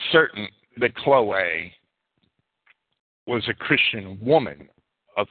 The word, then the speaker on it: Chloe: Chloe is the way I would pronounce the Greek form of the word.